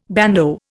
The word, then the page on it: bandeau: Indian